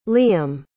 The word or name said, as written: liam